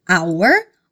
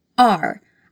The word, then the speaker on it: our: Our, que significa nosso, pode ser pronunciado de duas formas diferentes. Você pode dizer our ou você pode dizer our. Aqui só depende de como soa melhor para você, o que fica mais natural.